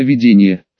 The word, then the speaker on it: видения: И, конечно, немного с моей подачи, но она в первую очередь затеяла разбор и изучение довольно мутного по описаниям заклинания видения, с ударением на первом слоге.